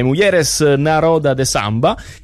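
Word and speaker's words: Mulheres na Roda de Samba: Un evento dal nome complicato, mi perdonerete se la pronuncia non è perfetta, quindi lo dirò un po' come mi viene: Mujeres Naroda de Samba. E mi faccio dire se è corretto la pronuncia dall'organizzatrice. Carol Mello, buongiorno. – Buongiorno, no, è perfetta.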